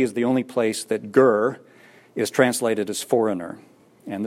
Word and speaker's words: ger: The Hebrew word for foreigner is the word ger, G-E-R, ger.